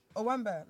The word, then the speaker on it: Owambe: No, it's Owambe. I already said it. That's say it again. Like, why so quick? – Owambe. Like, that's how you say it.